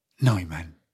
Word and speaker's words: Neumann: Con parole tue, Neiman. – Neumann. – Come? – Neumann. Si pronuncia Neumann.